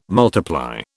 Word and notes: multiply: British English

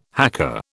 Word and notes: hacker: British English

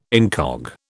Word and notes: incog: British English

